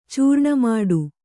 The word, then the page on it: ಚೂರ್ಣಮಾಡು: Kannada